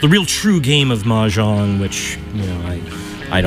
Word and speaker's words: Mahjong: How do you say that? Mahjong? – Mahjong.